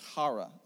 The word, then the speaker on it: chara: the word joy is spelled C-H-A-R-A in the Greek. So you could say chara if you're English, but it actually is hara, okay, in the original language.